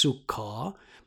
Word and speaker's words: Sukkah: The modern adapted spelling makes it look like Sukkot, or even Sukkoth. But the original Hebrew is pronounced, poorly by me, Sukkah.